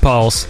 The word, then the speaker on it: PVLSX: Pulse, it's PVLSX, but it is pronounced Pulse.